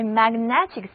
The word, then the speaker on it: magnetic: 所以我们也可以说 Paris is a magnetic city， Paris is a magnetic city，巴黎是一个迷人的城市。这里的单词 magnetic， M-A-G-N-E-T-I-C，字母 A 的发音仍然是梅花音，而字母 E 发小口的 A，而字母 I 的读音是短而急促的 E，整个单词重音在第二个音节，所以合在一起读作 magnetic。